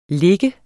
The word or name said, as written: lig